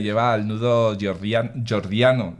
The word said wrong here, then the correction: Gordiano: ¿lo he dicho bien? Jordiano. – Gordiano, sí. – Gordiano, eso.